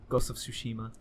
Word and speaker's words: Ghost of Tsushima: Ghost of Tsushima.